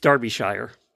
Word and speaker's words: Derbyshire: It's Derbyshire. She wanted to correct us on that.